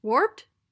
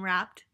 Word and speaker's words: Wrapped: How do you pronounce this? Warped? – Wrapped. – Wrapped? I'm so stupid.